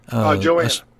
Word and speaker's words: Johanna: The song you chose is, I believe it's pronounced Johanna. – Johanna. – Johanna, excuse me.